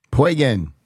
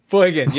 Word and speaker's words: Poygan: Lake Poygan? – Poygan. – Poygan, yes. – Rhymes with Sheboygan, that's how you can remember.